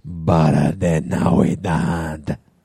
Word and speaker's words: Barra de Navidad: And they had set up this awesome party in this, like, Mexican bar restaurant in the town of Barra de Navidad.